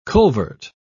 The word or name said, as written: culvert